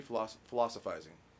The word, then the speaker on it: philosophizing: Did I say that right? Philosophy, philosophizing. Yeah, that's right. Okay, good job.